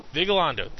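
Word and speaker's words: Vigalondo: Vigilando.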